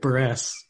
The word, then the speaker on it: Buress: Is that how to say his name? – Buress? I like Buress. Just Buress.